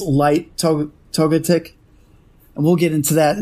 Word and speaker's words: Togetic: If I looked at it funny, I'm sure I would say it wrong, but I know that you pronounce Togepi, so you're like Togetic.